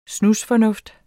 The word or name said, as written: snusfornuft